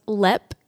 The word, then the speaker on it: Leap: But it is called Lepp Castle, which is spelled like leap. – Okay. – But I'm pretty sure it's pronounced Lep.